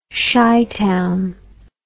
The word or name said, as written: chitown